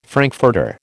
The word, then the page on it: frankfurter: American English